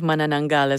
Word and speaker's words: Manananggal: And I gotta say that your pronunciation of Manananggal is quite impressive.